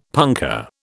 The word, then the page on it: punkah: British English